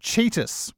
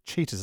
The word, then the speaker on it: Tsitas: And now it's pronounced Cheetus. We've checked apparently, even though it's spelled T S I T A S. Cheetahs is how you say his name.